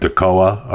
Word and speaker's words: Toccoa: Lisa wanted to let me know that it is the word T-O-C-C-O-A is pronounced Tacoa, right? Is that, did I do that right? The stress placed on K-O, so on K-O, right? So Tacoa, Georgia. That's how it's supposed to be pronounced.